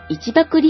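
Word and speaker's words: 一麦: そして今週のプレゼントは 2009 年版一爆リフィルです。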